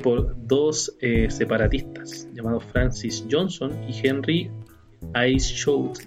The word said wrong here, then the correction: Ainsworth: No sé si estará bien pronunciado. Ahí damos un cubo. – Ainsworth. – Ainsworth, bueno.